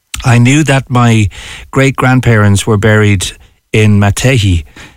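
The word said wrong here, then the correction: Matehy: Did I pronounce that right? Because I know that's a big Cork thing. – Nearly Mataha. – Mataha.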